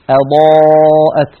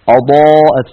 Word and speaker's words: أضاءت: أيضا من الإخلال بصفة الاستفال في الهمزة أن تنطق مفخمة مستعلية، خاصة إذا جاورت حرفا مفخمة، كقولنا مثلا أضاءت، فلما أضاءت ما حوله، بعض إخواننا يقولون أضاء أضاءت، فيفخم الهمزة آ، ولا تقول العرب آ بل تقول العرب آ أضاء، فلا بد من الحرص على استفالها وعلى ترقيقها وخاصة إذا جاورت حرفا مفخمة كما في المثال المذكور.